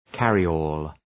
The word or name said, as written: carryall